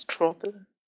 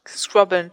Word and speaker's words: Scrobbeln: Genau, ich wollte schon sagen, das heißt Scrobbeln. – Wie? – Scrubbeln, wie du es genannt hast.